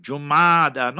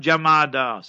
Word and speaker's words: Jumada: Jumada, not Jamada. Sometimes people write or say Jamada, that's incorrect totally. Jumadal Akhira. So that is the proper term.